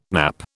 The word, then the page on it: nappe: British English